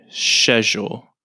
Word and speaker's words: schedule: The next one is schedule, but in British English, they say schedule.